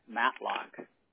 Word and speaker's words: Matlock: First name I have Steve, S-T-E-V-E. Is that correct? – Yep. – Okay. What is your last name? – Matlock. – Can you spell that for me, please? – M-A-T-L-O-C-K.